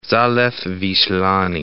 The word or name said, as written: Zalew Wislany